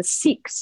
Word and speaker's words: Sikh: Like the Sikhs. So the Sikhs are warriors. The Sikhs is a certain religion and culture in India. It's actually pronounced Sikh, S-I-K-H.